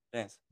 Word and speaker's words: Lens: Lens. – Lens.